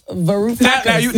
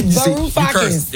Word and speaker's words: Varoufakis: Varoufakis. – Now they on stage. Right. Now they mad because you said their name wrong. – Varoufakis. You cursed on the radio 3 times already. – Varoufakis.